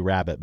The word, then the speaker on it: rarebit: It's a toasted cheese dish, and it's called Welsh rarebit. – Oh, sure. Uh-huh. – Or is it Welsh rabbit? – Yeah, rabbit. Like, I always understood it was supposed to be rabbit, but it's spelled rarebit.